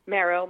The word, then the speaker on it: merrow: I wanted to ask you if you could help define for us the difference between mermaid, mermen, and maybe selkies and merrows. – Oh, sure. – So, So merrow, M-E-R-R-O-W, could be pronounced a number of ways, Mero.